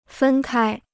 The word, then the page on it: 分开: fēn kāi